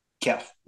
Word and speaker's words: Keff: That was Shane and Tibbs interviewing Keith. – Keff. – Did I say Keith? It's not Keith. It rhymes with chef. It's Keff.